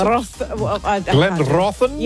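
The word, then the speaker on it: Glenrothan: Now, this is Glenrothen? – Yes. – That's how I pronounce it? – Glenrothen? Yes, thank you. Put a bit of Scottish in there.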